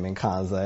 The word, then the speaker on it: Nkansah: If you were curious about the last name Nkansah and the pronunciation, it's actually a Ghanaian last name.